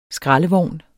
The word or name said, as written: skraldevogn